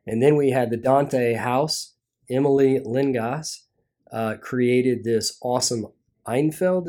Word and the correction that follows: Enfield: is what we call that. – Enfield will be it. – Yeah, the pronunciation is difficult.